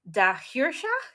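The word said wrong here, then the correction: dà-sheòrsach: he said I would pronounce it da hiorsach.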